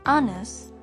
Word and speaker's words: honest: Hour and honest is a silent H.